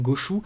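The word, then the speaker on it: gauchou (ゴーシュ): Oui, voilà, gauche, ou comme tu l'as dit en, version, en version originale, c'est la prononciation japonaise, donc gauchou.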